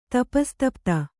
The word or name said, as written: ತಪಸ್ತಪ್ತ